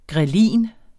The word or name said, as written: ghrelin